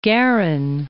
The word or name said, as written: Guerin